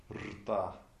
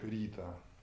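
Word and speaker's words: рта (ऋत): И вот риши или рта — это порядок, аналог космосу. А часто произносится как рита. Вот, это тоже слоговой «р».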